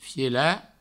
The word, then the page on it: fielat: Provençal